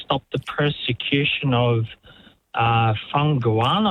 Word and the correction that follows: Falun Gong: I think it's pronounced. – Falun Gong, it'll be.